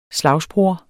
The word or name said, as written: slagsbror